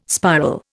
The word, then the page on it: spiral: Indian